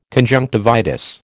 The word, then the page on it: conjunctivitis: American English